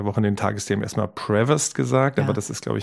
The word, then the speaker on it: Prevost: Also es gibt, wie gesagt, vorne die Betonung oder hinten, aber du würdest sagen, man spricht den neuen Papst mit bürgerlichem Namen aus. – Prevost, würde ich auf amerikanisch.